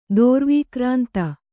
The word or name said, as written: ದೋರ್ವೀಕ್ರಾಂತ